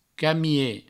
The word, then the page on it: camie: provençal